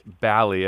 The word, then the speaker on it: Balli: So I've already said your name wrong in this opening, Dominic Balli, but it's actually Dominic Balli, as in Cali, correct? – Balli like Cali. That is correct.